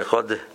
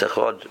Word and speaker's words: echod: A lot of people pronounce the word echod, there's no shema under the dalad. It's echod.